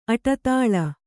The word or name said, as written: ಅಡಿದಳ